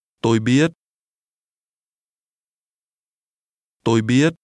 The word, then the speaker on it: biết: Tôi không biết. – Repeat after the speaker. – Tôi không biết. Biết. Biết. – The Vietnamese word no is pronounced with a high rising tone.